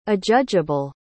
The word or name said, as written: adjudgeable